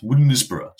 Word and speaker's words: Wodensborough: There's a place near me which is unfortunately pronounced Woodensborough, so you can't really appreciate it, but it's spelt Wodensborough.